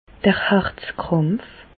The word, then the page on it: d'r Harzkràmpf: Bas-Rhin; Reichshoffen